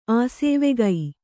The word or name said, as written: ಆಸೇವೆಗೈ